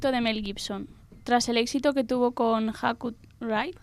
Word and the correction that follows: Hacksaw Ridge: ¿Alguien lo puede pronunciar mejor? – Sí, por favor. ¿Cómo? – Es Hacksaw Ridge.